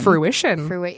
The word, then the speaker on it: fruition: And just wish me luck when that finally comes to fruitation. – Fruition. – Is that really how you pronounce it? – Fruition.